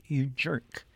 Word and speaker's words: UJ3RK5: That was a former band of mine called UJ3RK5, and the five is silent, so that's You Jerk. – I said You Jerk, but occasionally I say You Jerks.